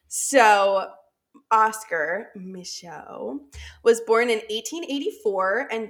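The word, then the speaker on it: Micheaux: It's totally a French last name, so it's M-I-C-H-E-A-U-X. – Michaud. – That's Michaud. Yeah, I Googled the pronunciation of it. Because I didn't want to go Michooks. I could tell it was not going to be right.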